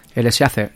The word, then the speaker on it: Eraserhead: David Lynch ya venía de 3 años atrás de filmar Cabeza Borradora, Eraserhead, no sé si lo digo bien. Eraserhead, eso.